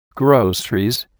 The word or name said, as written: groceries